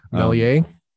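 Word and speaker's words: Méliès: Even Millais, how do you say his name? Millais. – Millier? – Thank you.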